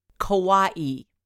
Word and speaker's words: kawaii: The back of the box says the art style is kawaii, which is pronounced like Hawaii.